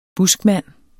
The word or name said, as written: buskmand